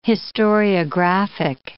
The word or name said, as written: historiographic